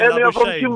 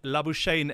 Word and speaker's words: Labuschagne: So in Australia, they say Labuschagne.